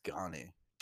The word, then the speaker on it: Gane: Fucking can't wait to see Ngannou versus Gane. That's gonna be good. Like, I guess it's Gane. – Gane? I say Gane. – What fucking Shiloh says? He's all, it's Gane.